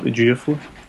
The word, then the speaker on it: Ejiofor: I say Chiwetel Ejiofor.